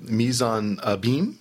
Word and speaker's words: mise en abîme: And you use a term, a formal term that I was unfamiliar with, mise en abîme.